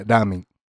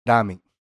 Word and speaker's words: ramen: E eles falam ramen. Essa é a pronúncia certa. Que não é nem L nem R. É ramen. – Ramen.